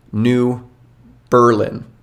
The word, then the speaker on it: New Berlin: New Berlin, and it's Berlin, not Berlin.